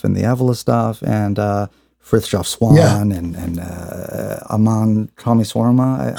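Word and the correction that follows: Ananda Coomaraswamy: Ananda Kumaraswamy.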